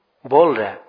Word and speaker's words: bowl: bowel. So immediately, the four directions protectors, land protectors, brought a different, four different bowels. The, bowls. Bowels, yeah? So, is that correct pronunciation? Bowels? Bowl. Okay, bowl.